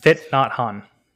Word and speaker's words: Thich Nhat Hanh: So this is a quote by I'm gonna butcher the name. It's Thich Nhat Hanh. – Thich Nhat Hanh. – Yes, thank you.